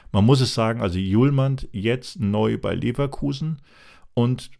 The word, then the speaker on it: Hjulmand: Also er schreibt sich ja H-J-U-L-M-A-N-D, also H-Julmand habe ich ihn zuerst gelesen, aber heißt einfach Julmand, Kasper Julmand.